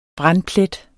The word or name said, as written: brandplet